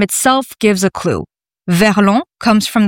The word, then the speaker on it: verlan: Can you explain what verlan is? Have I pronounced that right? Verlan? – Yes, correct.